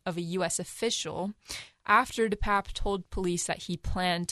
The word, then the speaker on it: DePape: So, first of all, David DePape, which we now know the pronunciation of his last name, it is DePape.